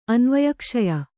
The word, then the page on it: ಅನ್ವಯಕ್ಷಯ: Kannada